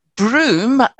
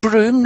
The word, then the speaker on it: Brougham: Now, Broom, he's called Broom. Nobody knows how to pronounce his name. It's spelt B-R-O-U-G-H-A-M, but we know for sure that it was pronounced Broom.